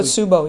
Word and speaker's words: Takotsubo: Am I pronouncing it correctly? – Takotsubo, yes.